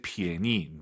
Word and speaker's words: 便宜: P-I-A-N-Y-I인데요. 절대 便宜, 니은 발음 들리면 안 돼요. 便宜. 便宜, 요거 기억하세요.